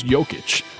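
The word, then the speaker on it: Jokić: His name's Jokic, but it's spelled with a J.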